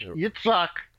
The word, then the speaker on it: Yitzhak: And the pronunciation has changed from the Hebrew to the English. It's Yitzhak.